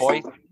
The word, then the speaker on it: Boise: It's right? Boise. It's Boise. Like, it's pronounced like the word boy and then the letter C.